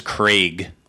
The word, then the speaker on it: Craig: It's actually pronounced Craig. – Craig. Are we in Scotland? – Craig. Like it is. – Craig. – It's Gaelic. Craig.